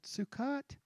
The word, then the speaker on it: Sukkot: I said, Sukkot? Did I say it right? She said, you said that perfectly.